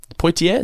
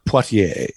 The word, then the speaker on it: Poitier: Poitier, is that how you say it? – Poitier.